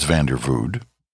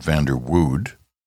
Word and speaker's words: Vanderwood: I think it's Vandervood, not Vanderwood. Vandervood, I think you pronounce that W like a V, I think.